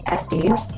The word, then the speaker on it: Estes: David Estes.